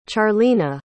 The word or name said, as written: charlena